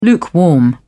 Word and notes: lukewarm: British English

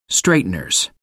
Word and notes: straighteners: American English

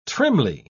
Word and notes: trimly: British English

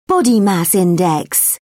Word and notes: body mass index: British English

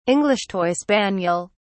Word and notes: english toy spaniel: British English

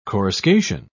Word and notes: coruscation: British English

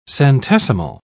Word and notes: centesimal: British English

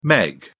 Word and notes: meg: British English

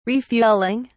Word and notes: refueling: British English

